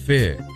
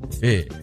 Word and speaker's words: Feux: Actually, I had to get some help from Google Translate to help me pronounce that properly. Fu. F-E-U-X. Fu.